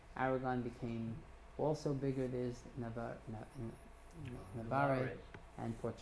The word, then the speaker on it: Navarre: Then you had Aragon, and then Navarre, and how do you say it? – Navarre.